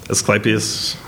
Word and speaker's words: Asclepius: So this is Iceburn's most recent album, Asclepius, is that how you say it? – Asclepius, from 2021.